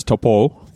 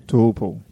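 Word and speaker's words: Taupō: So they've rebranded and they've made sure that's Taupo, the Maori. – Taupo. – Yep. The Maori pronunciation of it, and everywhere you go, it's got the O with the line across it.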